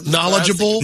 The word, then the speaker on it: knowledgeable: That's knowledgeable. – Knowledgeable. That's a silent K word.